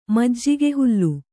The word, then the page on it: ಮಜ್ಜಿಗೆಹುಲ್ಲು: majjige hullu